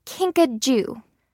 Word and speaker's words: kinkajou: I think you say kinkajou. – Okay. And how do you spell that? – It's spelled K-I-N-K-A-J-O-U.